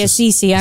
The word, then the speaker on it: Assisi: So you go with Assisi or Assisi? – No, I say Assisi. I was saying how you say it.